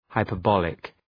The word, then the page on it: hyperbolic: {,haıpər’bɒlık}